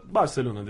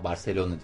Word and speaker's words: Barcelona: Ege diyor ki mesela Barcelona diyor. Hayır öyle denmez. Barcelona. Bak. Barcelona. Barcelona. – O senin dediğin İspanyol aksanı değil. Peltekliç.